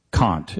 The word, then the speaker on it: Kant: No, it's not okay to say Kant. That's just wrong. It's done, but no, Kant is the correct pronunciation.